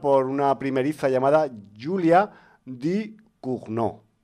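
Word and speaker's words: Ducournau: Que por el nombre podría parecer de Rumanía, pero no, es francesa, ¿vale? Di Cugnot. Lo que pasa es que como se escribe Du Cournau, pues igual puede dar lugar a, digamos, a algún desliz, digamos, geográfico.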